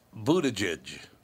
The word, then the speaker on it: Buttigieg: And it's Buttigieg. – What? – Buttigieg is how you say his name. It's not.